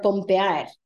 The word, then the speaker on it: pompeada: Coming from the verb pompear, pompear. And you would say, estoy pompeada. Estoy, you know, the D wouldn't be pronounced, it's just pompea.